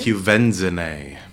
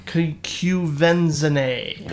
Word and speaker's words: Quvenzhané: Quvenzhané. – Quvenzhané.